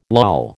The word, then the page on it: loll: American English